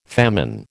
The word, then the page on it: famine: American English